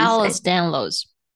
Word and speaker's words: Ehlers-Danlos: How do you pronounce it? Can you please Ehlers-Danlos.